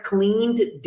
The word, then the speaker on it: cleaned: A área do chuveiro ali, that are cleaned daily. Então, são limpos ou limpados, nesse caso, né? Limpos, enfim, só falei clean é limpar. Cleaned, aqui também você não vai pronunciar o E, então cleaned, são limpos diariamente.